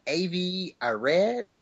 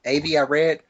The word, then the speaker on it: Avi Arad: A guy named Avi Arad, I think that's how you pronounce his name, Avi Arad, will be producing it.